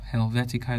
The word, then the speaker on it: Helvetica: Are you pronouncing it wrong? Don't mind me with that. That is Helvetica. Helvetica is quite popular, and my favorite one among that phone group is Helvetica Neue, which is a variant of Helvetica.